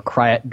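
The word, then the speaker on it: Krayt: And just so everybody knows, it's that dragon thing, which fans will know as the Greater Krat Dragon. Okay. Or Kryat Dragon, I've heard it pronounced all sorts of ways, but it's that skeleton that's lying in the desert when C-3PO turns around and sees the Jawa transport.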